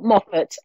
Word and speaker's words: Moffat: Gail Moffat, MOFFAT. And it's spelled the same way as town in Scotland.